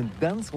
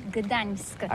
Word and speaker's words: Gdańsk: Ça s'écrit G-D-A-N-S-K. On dit Gdansk ou Gdansk, on prononce le G ou pas?